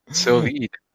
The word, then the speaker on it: sous-vide: It's pronounced sous-vide.